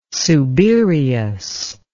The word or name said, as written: subereous